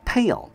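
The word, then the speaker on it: pail: 这个 a-i 它发的是长音 long a。 pale， pale， bucket 和 pale 都指的是有提梁的桶子。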